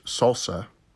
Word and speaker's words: SLSA: Google launched a new proposed solution to software supply chain attacks called SLSA, pronounced SALSA.